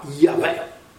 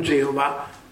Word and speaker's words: Yahweh: La pronuncia antica, ma le trascrizioni greche ci dicono che il nome santo di Dio si pronunciava Yahweh, oppure qualcuno dice Yahoh, non certo Geova, perché voi sapete Geova è una balla inventata dai nostri amici testimoni di Geova, i quali fanno una cosa assurda per l'ebraismo.